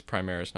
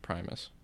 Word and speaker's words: Primaris: No, I want to confirm it's Primaris, not Primus. Because it's going to bug me if I say it the wrong time throughout the entire podcast. – Oh, God. Like, Robute? – Robute, that's fine. That's how it's said, so. – I'm kind of glad they did come up with that. – Primaris, though. I was right. Primaris Space Marines.